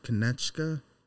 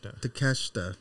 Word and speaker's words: Takeshita: Ospreay and Kaneshka. Am I saying that right? – Takeshita.